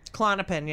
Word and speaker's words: Klonopin: Klonopin. Yeah. – I was on that. – Not Klonopin. – What is it? – Klonopin.